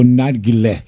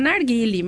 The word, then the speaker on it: narguile: É narguile ou narguilé que nós falamos? – A pronúncia... Eu costumo pronunciar narguile, mas narguile. Eu já ouvi bastante narguilé.